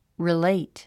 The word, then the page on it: relate: riléit; リレイト